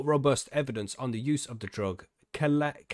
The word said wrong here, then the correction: Celadon: I'm going to spell it for you because I'm butchering however that's spelled. It's Celadon. Celadon. Yeah, mate. Yeah, Celadon Pharmaceuticals is now starting its first clinical trial of its kind in 5,000 patients with chronic pain.